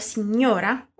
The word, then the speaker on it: signora: Você notou também a palavra signora? O que tem de especial nela? A pronúncia é do GN, que a gente vai falar como se tivesse um NH ali no lugar.